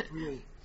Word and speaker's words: Peru: Our final case for this morning is Lloyd against Mayor of the City of, I'm sure it's pronounced, well, it looks like Peru to me, but you know, then I think of Cairo and other sorts of places. – Peru is Peru.